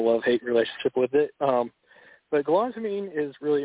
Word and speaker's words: galantamine: It's galantamine? – Yeah, galantamine.